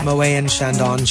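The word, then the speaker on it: Moët & Chandon: During the auction part of the evening, the item up for bid was a bottle of Moët and Chandon. Is that how you say it? – I think so, yeah. – Moët and Chandon.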